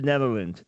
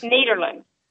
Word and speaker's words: Nederland: Kathy, is it Nederland or Netherland, Texas? – Nederland.